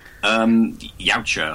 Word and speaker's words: Yautja: Between me and you, do you have any hopes at some point in the future to maybe have the Yuchi? Is that how you pronounce the Predator's race? – Yautja, I think they're pronounced.